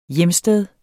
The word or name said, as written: hjemsted